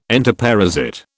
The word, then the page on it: entoparasite: British English